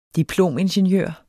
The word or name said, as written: diplomingeniør